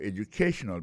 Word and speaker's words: educational: educational background alors accent tonique là côté opposé public là il tombe sur troisième syllabe